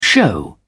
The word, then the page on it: show off: British English